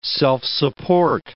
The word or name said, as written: self-support